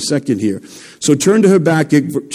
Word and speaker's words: Habakkuk: I can't even pronounce it sometimes, but it's Habakkuk.